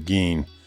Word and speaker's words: Gein: Later on, when he was going through his trials and all, he had a brief press conference, and The press asked him the proper pronunciation of his name, and he said, "Well, some say Gein." He said, "We, or I, have always said Gein." He said, "I don't know, it's about 50-50.